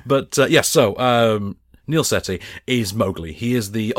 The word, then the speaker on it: Sethi: It's Neil Sethi. – There is an H in there. Is it Silent H? – Silent H.